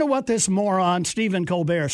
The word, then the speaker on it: Colbert: I know, he likes to use the affectation Stephen Colbert, even though the other members of his family pronounce their last name Colbert, was using the funeral and the lying in repose at the nation's capital of George H.W.